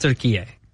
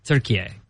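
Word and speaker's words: Türkiye: They pronounce it turkey A. – Turkey A. – Okay. Like, so over there, it's pronounced turkey A.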